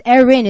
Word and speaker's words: Erin: And a lot of Koreans can't pronounce it because Erin is really hard to say.